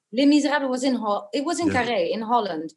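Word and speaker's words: Les Misérables: Les Miserables. It's your accurate French pronunciation. It's what we English call Les Miserables.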